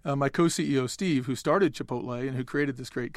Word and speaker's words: Chipotle: Chipotle, which I assume that since you're saying it the same way I am, we're saying it correctly.